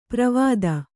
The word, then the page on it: ಪ್ರವಾದ: pravāda